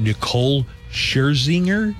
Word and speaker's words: Nicole Scherzinger: From Pussycat Dolls, do you say her name Nicole Scherzinger? – Yes.